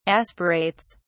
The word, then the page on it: aspirates: British English